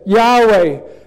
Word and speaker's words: Yahweh: People say now we should pronounce that Yahweh, Jehovah the I Am who I am.